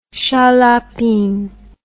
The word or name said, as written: Shaliapin